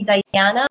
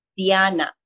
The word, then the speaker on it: Diana: American people call me Diana, but it's Diana.